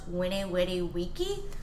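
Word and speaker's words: Veni vidi vici: My favorite Latin phrase is actually the Vene Vidi Vici phrase, because it's actually pronounced Vene Vidi Vici, which is like so like that's my favorite one, because like if you pronounce it correctly, it just sounds so funny.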